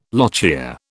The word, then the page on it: lochia: British English